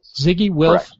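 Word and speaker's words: Zygi Wilf: Is that how you pronounce his last name? Ziggy Wilf. – Correct. Yes.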